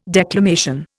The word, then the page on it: declamation: Indian